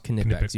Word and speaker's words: Knipex: And just let everybody at home know it's spelled Knipex if you're trying to look it up, and it's pronounced Knipex.